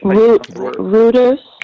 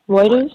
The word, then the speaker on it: Reuters: And as many linguists and individuals that we have affiliated with the BBC and that are affiliated with, how you pronounce it, R-E-I-T-E-R-S, you know, for the journalism, R-E-I-T-E-R-S. – Reuters? – Right. – Reuters? – Right. I normally say Reuters, these, but...